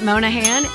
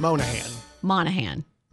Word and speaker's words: Monahan: Pat Monahan is 50. I know I said it wrong. – Monahan. Okay.